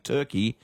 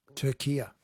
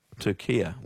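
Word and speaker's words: Türkiye: But in yesterday's Socceroos updates, you kept saying Turkey and not – Turkia. – Turkia. What's doing? I'd never heard of Turkia, Ryan, is my honest answer. – Yeah. – Never, ever, ever heard of it pronounced that way.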